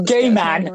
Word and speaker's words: Gaiman: Because if you pronounce it right, it sounds like gay man. And I was like... – So okay. Remember that. – Neil Gaiman.